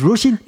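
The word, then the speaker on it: Róisín: She, she says Roisin. – Yeah, it's Roisin. – There you go. There you are. I knew that. People get me in the background. That's not the way to pronounce it.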